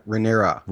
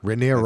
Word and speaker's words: Rhaenyra: Yeah, Rhaenyra. – Rhaenyra.